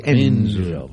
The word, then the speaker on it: Enzo: Hay que pronunciar así, Enzo. – Porque tiene esa cosa italiana, ¿eh? – Pérez, lo dejamos de lado.